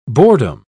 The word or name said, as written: boredom